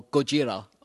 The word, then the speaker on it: Gojira: It's the original Godzilla or Gojira, I believe, is the Japanese pronunciation, but I will refer to it solely as Godzilla.